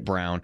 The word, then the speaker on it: Braun: It's spelled Braun. We say it Brown from Kansas.